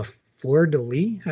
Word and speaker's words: fleur-de-lis: They did the, is it called a fleur-de-lis? I think is how you say that.